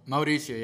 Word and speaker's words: Mauricio: Mauricio, yeah. – You roll your R's.